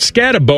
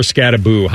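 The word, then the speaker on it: Skattebo: So John Harbaugh saw his running back, Cam Scadabo. Is it Scadabo or Scadaboo? How are they pronouncing it now? – Boo. – They're going boo.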